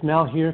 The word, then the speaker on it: Malheur: Well, it sounds like he isn't so sure at first, but and you're right, you pronounce it right. It's Malheur County, and he's the sheriff in that county.